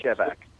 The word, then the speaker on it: Shevack: S-H-E-V-A-C-K is how you pronounce, how you spell Michael's name. The Six Fix. – I pronounce it Shevak.